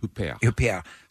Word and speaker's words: Huppert: Huppert.